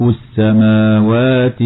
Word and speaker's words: السماوات: رسم كلمة السماوات إذا أتت معرفة بلام التعريف بحذف الألف التي بعد الميم وكذا الألف التي بعد الواو، مع وجوب النطق بهما مع مدهما مدا طبيعيا.